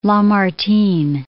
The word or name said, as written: Lamartine